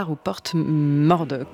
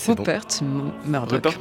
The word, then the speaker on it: Murdoch: son biopic consacré à Rupert Murdoch. J'ai raté la prononciation du nom, vous allez peut-être me reprendre. – Rupert Murdoch.